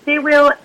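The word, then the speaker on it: Cyr wheel: Is that pronounced Sia wheel, the Sia wheel, and the tipping ring? Could you explain what those are, please? – Yes. So Sia wheel is like a big metal ring that spins on the floor.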